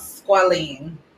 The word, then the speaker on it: squalene: How do you pronounce it? – Let me see. It says squalene. – Squalene. Okay.